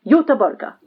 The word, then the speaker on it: Göteborg: Nay, Gothenburg. So we pronounce it Göteborg.